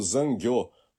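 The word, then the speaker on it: zangyo (残業): Sim, Sônia, você fez a pronúncia certíssima do decasseguês, que é o zangyo, com o A anasalado, né? An, e como se tivesse um tio em cima do A, né? E o acento circunflexo em cima do último O, né? O. Então, diferente dos nativos japoneses que pronunciam zangyo, né?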